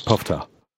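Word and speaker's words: poofter: Puffta. It's not pronounced like roof. The vowel sound is the same as the one in book.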